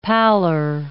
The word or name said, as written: pallor